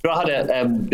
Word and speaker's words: Drogheda: Drogheda, believe it or not, the G is silent. I understand why you'd want to focus in on the G, but it's silent.